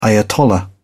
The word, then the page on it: ayatollah: British English